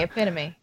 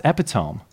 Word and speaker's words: epitome: I've heard it pronounced epitome. – Nope. – Now we're going to settle this on the spot. – All right, I'm right. – You are right. – It's epitome.